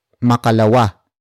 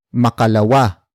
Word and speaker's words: makalawa: And it will be at makalawa. Makalawa. Makalawa. Makalawa is spelled as M-A-K-A-L-A-W-A.